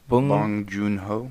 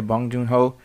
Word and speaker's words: Bong Joon-ho: Bong Joon-ho. – Yeah, Bong Joon-ho.